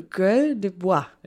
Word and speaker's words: Gueule de bois: Wenn Franzosen einen Kater haben, sagen sie, sie hätten einen Gueule de Bois, eine Holzfresse, würde ich jetzt mal so aussprechen oder? Gueule? Gueule de Bois.